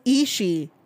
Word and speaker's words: Ishii: For the record, guys, the way you pronounce Ishii is Ishii. No Ishii, just Ishii. That's all you have to say. Ishii. Do not say Ishii during Forbidden Door. It is just Ishii.